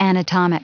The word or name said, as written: anatomic